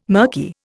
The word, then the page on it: mirky: Indian